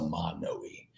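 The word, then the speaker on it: Manoi: Yeah, in Hawaii, the vowels have to be specifically pronounced. So Manoy.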